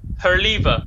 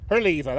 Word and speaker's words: Herleva: Herleva. Her lever. that's right. Her, yeah. – It was spelled H E R L E V A. So I'm not exactly an expert on how the Normans would have pronounced that, but it was H E R L E V A.